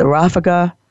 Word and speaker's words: ráfaga: In Mexico, we call it the ráfaga. I love that word, the ráfaga. Ráfaga, you can roll your R's.